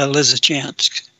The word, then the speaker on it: Lysychansk: Lysychansk. – Yeah, Lysychansk.